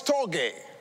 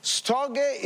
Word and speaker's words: storge: The second one is storge. Now, of course, I'm not Greek. Maybe my pronunciation may not be correct. It's spelled S-T-O-R-G-E. Storge.